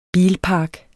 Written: bilpark

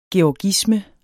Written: GEORGISME

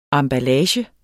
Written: emballage

